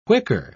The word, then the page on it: whicker: British English